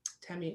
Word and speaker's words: Temi: Temi and not Tammy, because it's spelled with an E.